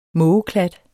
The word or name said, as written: kladde